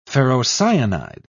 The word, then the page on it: ferrocyanide: British English